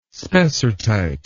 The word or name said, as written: spessartite